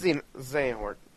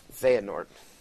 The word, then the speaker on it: Xehanort: Xenohort. How do you pronounce it? – Xehanort. – What? – Xehanort. – Okay.